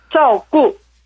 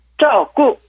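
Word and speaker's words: 짜오꾸 (照顾): 발음은 둘 다 사성에서 짜오꾸 짜오꾸 라고 합니다.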